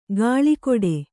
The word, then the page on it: ಗಾಳಿಕೊಡೆ: gāḷi koḍe